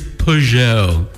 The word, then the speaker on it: Peugeot: It is spelled P-E-U. – Peugeot. It's Peugeot.